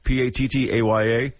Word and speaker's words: Pattaya: How do you pronounce that? Pattaya.